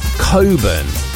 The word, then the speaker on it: Cockburn: Olivia Wilde, her real surname is Coburn, but it's spelt Cockburn, and she had to change it for Hollywood.